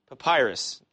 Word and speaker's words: papyrus: A shorter but similar exorcism is found in the same, how do you say that word? P-A-P-Y-R-U-S. Papyrus. Is it? Okay, thank you.